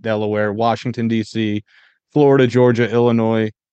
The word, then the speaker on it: Illinois: Illinois is weird with putting an S at the end and not pronouncing it.